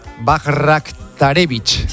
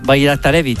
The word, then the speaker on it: Bajraktarević: Porque El primero es un bosnio del PSV que se llama, voy a decirlo tal y como se escribe, Esmir Bajraktarević. – Sí, señor, Bajraktarević.